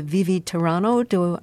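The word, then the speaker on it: Vivi tiranno: This is from Handel's opera Rodolinda, and this is the Vivi Tirano. Am I pronouncing that correctly? – Perfect.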